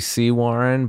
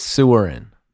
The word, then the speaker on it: Sewaren: It was technically Sea Warren, but if you look at it, it says Sewerin.